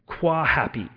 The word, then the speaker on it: Quahappy: I think it's pronounced Qua Happy. It's Q-U-A Happy. So Q-U-A-H-A-P-P-Y.